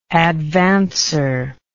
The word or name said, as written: advancer